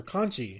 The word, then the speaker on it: Conti: Tenara Conti versus, or Conchi, I think the T is like a ch, like a little bit of a ch. At least that's how Mara was pronouncing it.